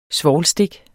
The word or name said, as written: svovlstik